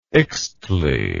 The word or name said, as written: ixtle